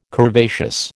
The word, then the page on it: curvaceous: American English